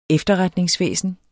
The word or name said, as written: efterretningsvæsen